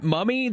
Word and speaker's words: Mumme: I'm following Matt Mumme, the offensive coordinator. Am I saying that right, Kevin? First off, yeah.